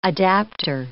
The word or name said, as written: adaptor